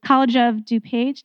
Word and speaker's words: College of DuPage: College of DuPage, did I say that right? Yes.